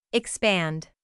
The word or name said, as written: expand